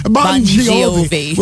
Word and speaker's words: Bon Jovi: And in music, besides John Biol, say his name again. – Bon Jovi.